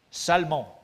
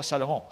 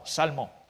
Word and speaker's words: Salmon: Salmon, pas Salomon, Salmon, engendra Boaz de puis là, en plus, je, vous ouvre une parenthèse encore plus drôle.